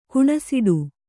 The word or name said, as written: ಕಣ್ಸೆಡೆ